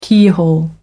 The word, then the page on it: keyhole: American English